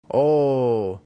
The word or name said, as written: o